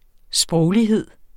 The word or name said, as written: sproglighed